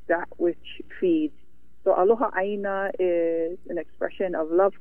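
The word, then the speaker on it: aloha aina (aloha ʻāina): You describe it in terms of aloha aina, is that right? Love of the land, am I pronouncing that correctly? – Yep, that's right.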